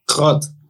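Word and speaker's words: Grot: Grott, Grott wie, wie spricht man das aus? Grott. Grott. Gut, und Grott hat äh, noch keine Minute äh, gespielt.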